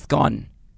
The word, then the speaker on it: scone: So you pronounce it scone. Scone. Not scone.